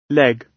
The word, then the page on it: leg: British English